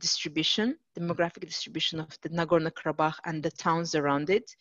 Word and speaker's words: Nagorno-Karabakh: And just to track back, so Nagorno-Karabakh, is that how you pronounce it? – Yes, Nagorno-Karabakh.